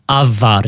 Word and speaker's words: Ajvar: Ajvar. – But it's Avar is how you say it. – It's Avar. – Avar. Yeah. – Because the J is a Y.